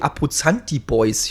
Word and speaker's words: Abruzzanti Boys: Und zwar haben die Abruzzanti Boys, ich habe es wahrscheinlich jetzt wieder falsch ausgesprochen, das müsste richtig sein.